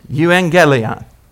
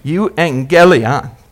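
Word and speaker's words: Euangelion (εὐαγγέλιον): Say angel with a strong G. Angel. Euangelion. Put the emphasis on the gel. Euangel. Lion. Say lion. Euangelion.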